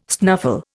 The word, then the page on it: snuffle: Indian